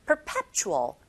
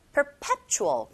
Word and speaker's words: perpetual: with 하실 때 입모양 잡아주시고 with, 혀끝이 윗니 아랫니 사이에 살짝 긁혀지고 perpetual, perpetual, 강세 꽝, 에, 이 부분 해주시고 snow 뒤에 on its head에서 on its head, on its head 이런 식으로 연결해서 발음하시면 됩니다.